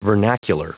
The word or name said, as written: vernacular